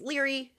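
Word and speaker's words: Laoghaire: It's L-A-O-G-H-A-I-R-E. So it's Leary, spelt a different way.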